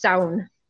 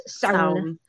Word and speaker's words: Samhain: Usually in Irish, it's Saun. It's solid one word, like clown, but with an S. It's Saun.